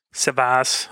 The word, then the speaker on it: Servas: Servas, also mit dem Z vorne ausgesprochen gibt es auch noch.